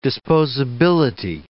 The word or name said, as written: disposability